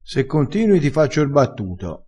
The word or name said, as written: fa r battuto